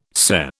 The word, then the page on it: SCE: British English